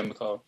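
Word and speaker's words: McAuley: Now, pronounced that right? Pascal's Angel, right? – Yeah, Macaulay. – That's right.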